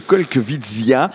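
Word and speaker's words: Kolkwitzia: Olivier, vous avez également pensé à nous présenter dans cette émission un arbuste peu connu, le Colquevitzia. Disons-nous plus sur cet arbuste originaire de Chine. J'espère que je l'ai bien prononcé. – Alors oui, tout à fait.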